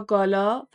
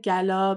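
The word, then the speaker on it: gala: و حالا اینکه چه ربطی به اخبار و چیزای امروزی ما داره این حرفا، برمیگرده به متگالا یا گالا، فرقی نمیکنه، جفتش صحیحه. گالا بیشتر تلفظ آمریکاییه، گالا تلفظیه که بریتیشه، بریتانیاییه.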